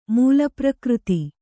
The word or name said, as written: ಮೂಲಪ್ರಕೃತಿ